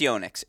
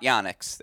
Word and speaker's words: Yonex: I know it's Yonex. It's not Yonex. It's a common misconception.